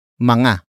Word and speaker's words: mga: It is the focus marker that introduces the main topic of the sentence, But before that, we have mga. Mga. Mga. Mga is spelled as M-G-A.